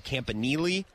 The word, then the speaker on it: Campanile: Anthony Campanile, I think that's the way that it's pronounced. Yep, that sounds right.